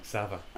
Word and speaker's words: Xaver: It is Franz Xaver. – Xaver. – I love it. That's how you pronounce Z, X-A-V.